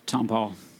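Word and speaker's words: Tompall: Tom Paul.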